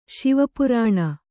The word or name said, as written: ಶೈವಪುರಾಣ